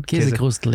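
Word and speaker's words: Käsekrainer: We are talking the Käsekrustel. Or is that how you say it? – Käsekrustel, yes.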